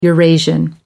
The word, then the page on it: eurasian: American English